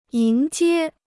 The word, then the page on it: 迎接: yíng jiē